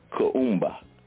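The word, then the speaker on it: Kuumba: Now, today's principle is the principle of Kaumba, the principle of Kaumba.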